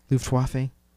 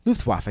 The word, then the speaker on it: Luftwaffe: Did I say it right? Fuck me. Luftwaffe? – Yeah.